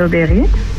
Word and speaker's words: Auberie: C'est Aubery. – C'est Aubery? – Oui. – Aubery, ça s'écrit comment? A-U-B-E-R-I-E? – Ouais, c'est ça.